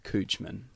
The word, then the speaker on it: Couchman: It's spelt Couchman. – Yeah. – How do you actually say your name? – My name is Scott Coochman.